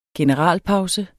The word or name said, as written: generalpause